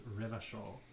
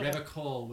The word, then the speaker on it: Revachol: Revachol. If you pronounce it Revachol with a hard K or hard C sound, that's like a...